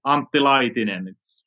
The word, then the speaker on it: Antti Laitinen: Well, it's almost Antti Laitinen.